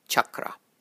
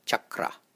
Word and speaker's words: chakra: The spelling for this word is C-H-A-K-R-A. Now in this one, both the A's are short. And the CH is pronounced as how you would pronounce the CH in the word chat, C-H-A-T, chat, not how you would pronounce shin or shout. So pay attention to how I pronounce the A's and the C-H. Chakra, chakra, as opposed to chakra.